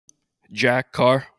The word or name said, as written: Jack Carr